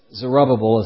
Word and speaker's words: Zerubbabel: And I mentioned also that chapter 4 is going to focus on a different person, that is Zerubbabel, something like that. You say it how you want to say it, he'll forgive you. But it's Zerubbabel is how I have come to say his name.